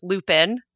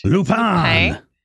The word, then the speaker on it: Lupin: Everyone calls it Lupin. – Lupin. – Not how you say it. – Lupin.